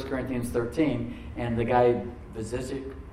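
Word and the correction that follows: Vujicic: how do you say it, Rachel? Where is Rachel? Vizicic, the guy with no arms and legs? Say it loud. Voyacek, yes, that guy.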